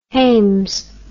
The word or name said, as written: hames